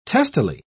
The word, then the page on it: testily: British English